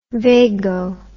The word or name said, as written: vago